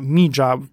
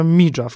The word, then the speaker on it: Mija: Dostajemy później obraz małej dziewczynki, niejaka Mija, ta tak się czyta, to się pisze Mija, ale to oni to wymawiają Mija w Korei Południowej, więc tak ona się nazywa po prostu.